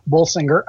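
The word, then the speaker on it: Bolsinger: Bulsinger. I think I pronounced that right. – Yes.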